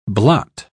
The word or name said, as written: blot